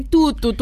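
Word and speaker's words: tutu: Τούτου, βρε, βρε, τι τούτου, τούτου. – Τι τούτου, τούτου είναι τα μάξι τα μορουδίστικα. Βάλε λίγο γαλλική προφορά. Τούτου.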